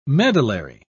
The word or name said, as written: medullary